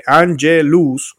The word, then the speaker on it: Angelus: Angelus, como lo estoy diciendo, como ángeles.